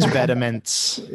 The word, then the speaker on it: Vetements: Oh no, you can't say that Vetmont's 71 out of 150 looks weren't good. – About, bro. – Do you even own any? No, I'm not paying $500 for the Ratatouille shirt. – It's pronounced Vetements.